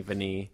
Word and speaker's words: Vinnie: Yes, rhymes with knee, Vinnie.